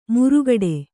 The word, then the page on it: ಮುರುಗಡೆ: murugaḍe